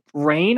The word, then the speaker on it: Rahne: So, when Ricky Rain, I believe is how you pronounce it, R-A-H-N-E, Ricky Rain, Ricky sure. Ron? – Yeah, go for it. Yeah, that sounds right.